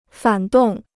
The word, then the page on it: 反动: fǎn dòng